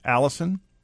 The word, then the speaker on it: allicin: Garlic has another sulfuric compound called allicin, pronounced like the name, allicin.